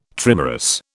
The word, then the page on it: trimerous: British English